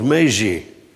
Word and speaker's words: Maisie: Trouwens, Martha wordt in patois uitgesproken als Maisie. En zo schrijven ze dan ook. M-A-I-S-I-E. Maisie.